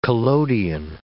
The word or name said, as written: collodion